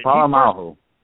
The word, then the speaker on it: Polamalu: I can never pronounce his name, Polamalu. Him retiring. – Polamalu.